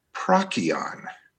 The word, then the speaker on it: Procyon: Procyon comes from the Latin. There are no soft C's in Latin. So the actual pronunciation from, a long time ago, and it's been modernized, and I'm okay with that. But the actual pronunciation is Procyon.